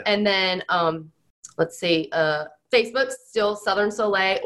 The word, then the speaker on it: Soleil: Soleil is spelled S-O-L-E-I-L, and it means sun. So Southern Sun, a lot of people don't know how to say it, but you will know how to say it.